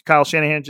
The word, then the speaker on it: Kyle Shanahan: Not crazy, not like holy shit, Kyle Han- Kyle Shanahan just say it wrong.